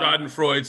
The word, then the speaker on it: Schadenfreude: And Schadenfreude, come on. – Schadenfreude, sorry. Thank you for the pronunciation.